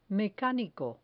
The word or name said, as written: mecanico